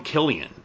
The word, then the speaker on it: Cillian: Yeah, I've heard people call him Killian. I've always referred to him as Cillian. – I think it's pronounced Killian, but I'm not 100% on that.